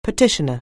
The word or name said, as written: petitioner